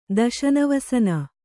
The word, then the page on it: ದಶನವಸನ: daśana vasana